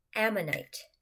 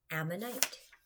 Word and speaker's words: Ammonite: Ammonite. Ammonite.